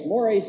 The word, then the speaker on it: mores: Social mores is spelled M-O-R-E-S. I, for years, thought my teachers were mispronouncing it, and it was just social mores. But you learn bit by bit that there are other languages besides English.